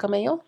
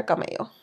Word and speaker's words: Cabello: I just called her a camello. – I mean, no, it's cabello. It's how it is in Spanish.